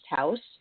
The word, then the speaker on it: Jaus: It's Ichiro Irie from House Gallery, spelled J-A-U-S, pronounced House.